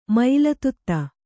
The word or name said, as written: ಮಯಿಲತುತ್ತ